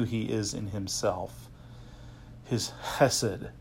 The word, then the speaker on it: chesed: Loving kindness is a translation of a Hebrew word pronounced chesed.